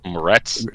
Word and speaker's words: Moretz: and that was Chloe Martez. Was that her name? Am I saying that right? – Moretz, yeah.